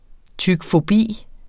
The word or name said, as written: tykfobi